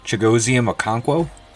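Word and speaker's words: Chigoziem Okonkwo: Chigozium Okonkwo? – Yes, that's it.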